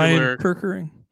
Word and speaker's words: Orion Kerkering: Oren Kerkering. If I'm mispronouncing that, is that's a popular Orion Kerkering? – Yeah, a popular dart.